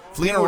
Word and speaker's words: Fleener: Fleener rhymes with Wiener, but go ahead.